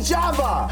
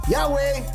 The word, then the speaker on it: Yahweh: Thy name be Java. – Hallelujah. – Not Yahweh, Java. That's how you say it. The Y is a J. The W is a V.